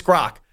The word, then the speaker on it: Grok: It's the Gork AI. Is that how you say it correctly? Do you have any idea how to pronounce Grok. – That? Grok? – It's like Gronk without the N.